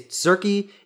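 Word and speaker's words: Tzurki: My username is Zurki. It's T-Z-U-R-K-I. It's hard to pronounce. I've been thinking about changing it, but it's like it's similar to Tzatziki, but to Zurki.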